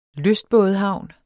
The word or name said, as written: lystbådehavn